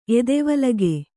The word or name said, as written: ಎದೆವಲಗೆ